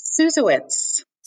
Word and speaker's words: Susewitz: Brandy, how do you say your last name? Susiewicz? Is that right? – Susiewicz.